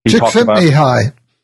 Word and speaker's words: Csikszentmihalyi: And there's Maslow's work was continued by, I can never pronounce this guy's name because it's Eastern European, Czesleminski. – Csikszentmihalyi. – Yeah, okay, thank you.